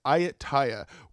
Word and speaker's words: Ayutthaya: Sorry if I butchered that, but it's Ayutthaya, which was like a super prosperous trade like city in Thailand.